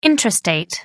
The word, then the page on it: intrastate: American English